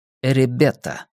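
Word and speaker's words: Эребета (エレベーター): «Эребета». Это название компании-изготовителя лифта, доставлявшего им столько хлопот, и так произносится на японском слово «лифт».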